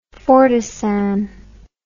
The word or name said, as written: fortisan